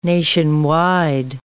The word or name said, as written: nationwide